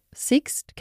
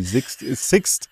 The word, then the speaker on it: Sixt: Mit Roland übrigens die Autofirma, die man auch unter Sixt kennt. – Ja, aber Sixt, Entschuldigung.